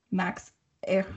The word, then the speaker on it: Ehrich: Uh, forgive me, Echrich. What is his name? It's E H R I C H. How do I say that? – Hold on, let me look it up. Demi Lovato. Max Ehrich.